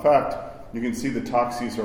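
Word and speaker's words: toxis (τάξις): Or they, the Greek word is a very Byzantine word too, toxis. You spell it taxis, but you don't pronounce it that way.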